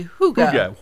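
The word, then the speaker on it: hygge: Yes, it looks like Heige, but it's actually Huga. – What is Huga knitting? – Well, it could be a couple different things, but when we're talking about Huga knitting, we're just talking about the feeling of Huga, which is just kind of a cozy feeling with congeniality.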